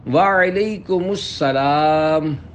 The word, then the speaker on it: وعلیکم السلام: سلام اور جواب سلام کا درست تلفظ یاد فرما لیجئے، پہلے میں کہتا ہوں آپ سن کر دہرائیے السلام علیکم، اب پہلے میں جواب سناتا ہوں پھر آپ اس کو دہرائیے وعلیکم السلام،